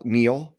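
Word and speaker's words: Neal: So I am the real Neil, that's N-E-A-L, and Schaefer is spelled S-C-H-A-F-F-E-R.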